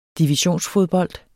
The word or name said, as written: divisionsfodbold